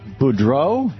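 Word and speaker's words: Boudreaux: And Professor Donald Boudreau, am I saying that right, Professor? – Yes, you did.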